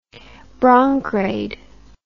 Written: bronkraid

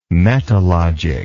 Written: metalogic

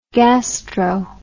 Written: gastro

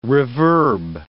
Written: reverb

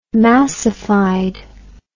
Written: massified

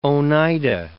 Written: Oneida

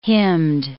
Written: hymned

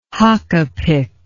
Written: hakapik

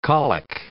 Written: colic

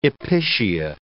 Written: episcia

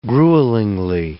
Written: gruelingly

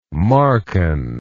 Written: Markan

